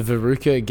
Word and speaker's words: RVCA: It's at the Veruca Gallery, which is, I don't know why it's called that, but it's spelt RVCA.